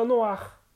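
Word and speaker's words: noire: Preto seria noir para masculino e para feminino a gente também fala noir, só que é acrescentado também o R no final. Um E, desculpa, é acrescentado um E no final. Porém, não há mudança de pronúncia. Noir, noir.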